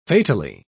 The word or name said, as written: fatally